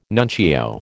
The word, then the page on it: nuncio: American English